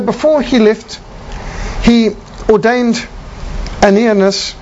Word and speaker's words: Ananias: And this first convert was Ananias, that's a better pronunciation.